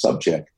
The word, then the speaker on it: Sobczak: And when you do that these days, it's just it's what Art Sobczak, uh, which is spelled S O B C Z A K, but it's pronounced Subcheck.